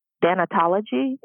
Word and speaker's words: thanatology: Now, as I stated earlier, you know, you're a gerontologist and a fellow in, am I saying this right? Thanatology? – Yes, you did.